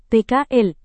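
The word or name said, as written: planet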